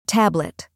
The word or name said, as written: tablet